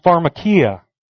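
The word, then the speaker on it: pharmakia (φαρμακεία): And that word, translated in the Greek, is pronounced pharmakia.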